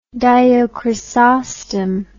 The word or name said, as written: dio chrysostom